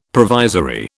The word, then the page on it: provisory: British English